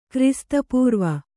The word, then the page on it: ಕ್ರಿಸ್ತಪೂರ್ವ: krista pūrva